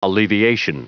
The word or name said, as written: alleviation